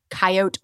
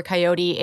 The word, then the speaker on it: coyote: She may have been, but, you know, the pronunciation of coyote or coyote, it's a regional thing. You know, someone actually did a survey on this in the United States, and it turns out that the three-syllable coyote is more popular on the coasts and in the south and southwest. But once you get to the plains, then you get that two-syllable coyote.